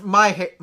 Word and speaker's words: Meijer: Oh, it's pronounced Meijer. Meijer.